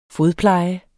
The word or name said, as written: fodpleje